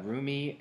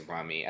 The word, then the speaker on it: Rumi: I don't think it's Rumi. I think it's Rumi. It's spelled R-U-M-I. That's where all, like, the blogs are saying. So, I don't know if it's Rumi or Rummy, but I'm thinking it's Rumi. And that's cute.